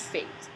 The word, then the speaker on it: F8: And it's F and then the number 8. And it's pronounced Fate.